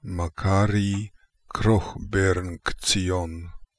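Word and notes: Krokhbernktsion Makary: Russian

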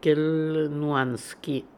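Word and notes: Kelnuanian: Russian

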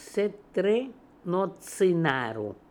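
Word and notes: Setrenotzinarco: Russian

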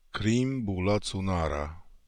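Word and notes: Kreemboolazoomara: Russian

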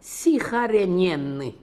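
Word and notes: Sikharenenian: Russian

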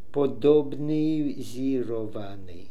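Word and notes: Podobnisirnian: Russian

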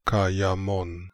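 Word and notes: Kaimon: Russian

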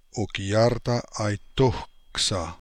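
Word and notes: Okiartaaitokhsa: Russian

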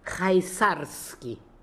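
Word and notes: Khaizarian: Russian

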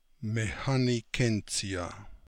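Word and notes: Mecanogentsia: Russian

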